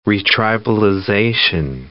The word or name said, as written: retribalization